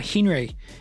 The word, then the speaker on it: Hnry: Before we go, I just wanted to remind you that today's episode is brought to you by Henry. That is spelled H-N-R-Y. There's no E in it, so it's H-N-R-Y. Henry is the trusted financial sidekick for contractors and freelancers.